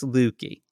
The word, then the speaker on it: Luckey: Her name is Ginger Lukey. I think that's how you pronounce it. L-U-C-K-E-Y. – When maybe you say it's lucky lady, it's Lukey. – It does actually look like Lukey.